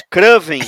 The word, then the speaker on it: Kraven: É Kraven. Ele muda a sílaba tônica e usa o seu sotaque gaúcho para nos seduzir e encantar.